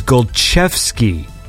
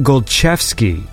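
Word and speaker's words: Golczewski: It is Golchevsky. like ch. It's a ch, ch sound. So it's Wojciech Golchewski, like that.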